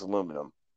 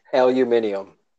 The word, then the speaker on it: aluminum: Oh, before I forget, I just want to say to anybody who might be listening, the correct way to say aluminum is aluminum. So just put that out there. – Aluminium.